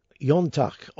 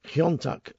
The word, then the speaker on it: iontach: Now, Yontak, or Kiontak as I was taught to pronounce it, is the Irish for wonderful or strange.